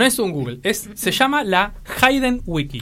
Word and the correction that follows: Hidden: Sí, Wiki, Hayden no, Hidden. Hidden, se pronuncia Hidden.